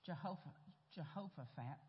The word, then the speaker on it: Jehoshaphat: And this was the story where Jehoshaphat, I said it right before I got up here. Jehoshaphat, thank you, Lord.